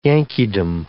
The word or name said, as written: Yankeedom